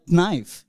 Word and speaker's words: knife: knife you know in knife k is silent um, and wife husbands are silent uh, sorry, it kind of be it's it's a bit of a uh, sexual.